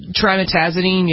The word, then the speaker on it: trimetazidine: Trimetazidine, is that how you pronounce it? – Trimetazidine, yeah, TMZ they call it.